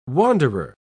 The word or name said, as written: wanderer